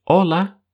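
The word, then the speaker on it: hola: И знакомое многим приветствие в Испании «Ола» тоже пишется через букву «H», но мы ее не произносим.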